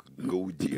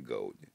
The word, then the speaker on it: Гауди: Как мне, да, как Гауди, а не Гауди.